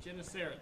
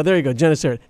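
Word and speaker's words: Gennesaret: Genesaret. – There you go. Genesaret.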